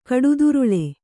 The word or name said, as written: ಕಡುದುರುಳ